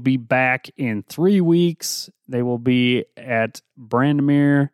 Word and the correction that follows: Bandimere: or Bandimere. I can't ever say that right. Bandimere for the Mile High Nationals in Denver, Colorado.